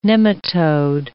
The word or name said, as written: nematode